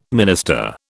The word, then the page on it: minister: British English